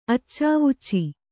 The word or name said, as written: ಅಚ್ಚಾವುಚ್ಚಿ